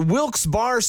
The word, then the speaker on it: Wilkes-Barre: And you're listening to Wilkes Barre Scranton's 97.9X. Now, I had heard that it was supposed to be pronounced Wilkes-Barre.